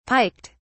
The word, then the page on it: piked: British English